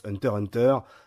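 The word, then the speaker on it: Hunter × Hunter: Oui, je prononce Hunter Hunter, c'est pas moi qui le dis, vous verrez ça tout à l'heure.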